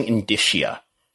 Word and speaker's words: indicia: Wrestling indicia. That's how you pronounce that word I learned the other day.